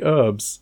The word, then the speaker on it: herbs: But they don't pronounce the H in America. They say herbs.